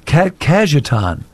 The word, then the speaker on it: Cajetan: Today, the church celebrates the feast of Saint, and you may have to help me out on pronunciation, Cajetan. – Cajetan.